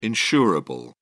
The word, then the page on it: insurable: British English